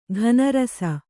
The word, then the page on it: ಘನರಸ: ghana rasa